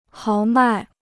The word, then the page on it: 豪迈: háo mài